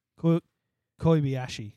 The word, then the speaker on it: Kobayashi: Kobayashi.